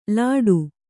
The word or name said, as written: ಲಾಟು